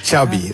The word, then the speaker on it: Xavi: Xavi.